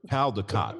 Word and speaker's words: Caldecott: Caldecott, right? – That's it.